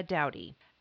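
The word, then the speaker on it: Doughty: I'm your go-to gal, Laura Dowdy. Okay, I want to tell you first and foremost, my last name is spelled D-O-U.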